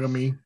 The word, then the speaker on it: Yagami: Yagami.